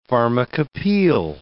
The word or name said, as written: pharmacopeial